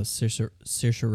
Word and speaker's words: Saoirse: I don't know how to pronounce it. Saurison? – Oh, Saoirse Ronan? – Saoirse. – Yeah, Saoirse Ronan.